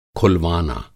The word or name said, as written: खुलवाना